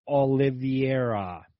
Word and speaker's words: Oliveira: That was the title fight against the champ, Charles Oliveira, actually is how you say it.